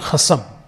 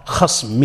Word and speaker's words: khasm: And that's a mispronunciation of khasm, meaning it's the same word, but in Asian languages, it's difficult to pronounce the sukun.